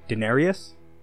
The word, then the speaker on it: Daenerys: Jon Snow, and I might be saying this name wrong. Daenerys? – No, you got it.